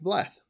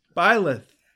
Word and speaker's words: Byleth: I really like Bleth, actually. Like it's this Byleth. – Oh, I thought Bleth. I even said it right this time. No, it's Byleth. Fine.